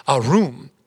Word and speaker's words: arum: That word crafty in Hebrew is pronounced arum.